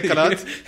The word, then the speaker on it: Kalat: ये स्पेलिंग बताएं क्या है कलत की. क्या KALAT. – हाँ ये कलत नहीं है. – हाँ ये कलात है.